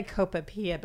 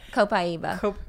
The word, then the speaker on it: copaiba: Like last night my shoulder was hurting, and he said, and I say it wrong, I say copaipa, but it's Copaiba.